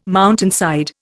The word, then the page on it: mountainside: Indian